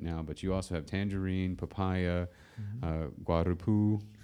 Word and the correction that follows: guapuru: Did I say it right this time? – No, almost. Guapuru. – Guapuru. – Guapuru. – Okay. – Yeah. – I got the right emphasis, but I put they inverted the two.